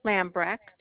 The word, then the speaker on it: Lambrecht: It's Amy Lambrecht.